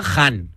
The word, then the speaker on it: Khan: He leído que se pronuncia Han. Si hay algún experto en persa que me corrija, pero en teoría pone que es Han.